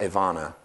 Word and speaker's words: Abana: Abana, it's pronounced Avana, is the Hebrew word for support.